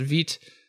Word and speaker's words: Vite: And yes, Vite, pronounced Vite, it's from French, I believe, means fast. And it is. – Yes, Vite rhymes with sweet is my way of remembering it. Nick Nisi says Vite rhymes with feet.